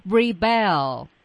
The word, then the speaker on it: rebel: 哎，这个词如果先给大家拼写的话，我相信同学们可能会知道它有两种读音、啊，来，它的拼写方式是 REBEL REBEL。好，我来为大家介绍它的两种词性。第一，它在做动词的时候读作 rebel，对 rebel，重音放在第二音节上。